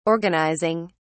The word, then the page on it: organising: British English